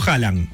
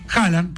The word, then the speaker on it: Haaland: ¿Cómo lo pronunciás vos? – Yo digo Haaland. – Haaland, perfecto. Haaland.